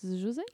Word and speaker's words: Josei: Comment ça se prononce Josei Josei, ça se prononce.